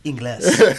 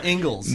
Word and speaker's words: Ingles: Every time I see Ingles, I always think it's Ingles. Ingles. That's the way it's spelled. I was like, hey, is that a Mexican restaurant? – Yeah. – Like, oh, no, it's Ingles.